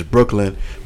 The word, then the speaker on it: BKLYN: His name is Brooklyn, which is spelled BKLYN.